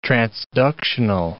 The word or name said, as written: transductional